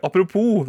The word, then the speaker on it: apropos: Apropos, det er stum S der. Det er verdt å merke seg hvis du f.eks. har norsktentamen i dag. Ja, god norsktentamen. hvis det er muntlig, så uttaler man ikke S-en i apropos.